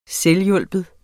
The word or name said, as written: selvhjulpen